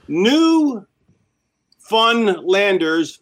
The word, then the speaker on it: Newfoundlanders: New Funlanders, phonetically spelled. Oh, Newfoundlanders, phonetically spelled. So it's spelled as Newfoundlanders.